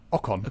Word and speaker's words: Ocon: I do like your pronunciation of Ocon.